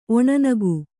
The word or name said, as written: ಒಣನಗು